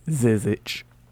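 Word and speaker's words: Žižić: Zizic. – Zizic. – Zizic. – Yeah, just pretend like there's an H on the end.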